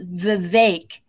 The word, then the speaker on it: Vivek: Let's just remember that Vivek is actually pronounced Vivek.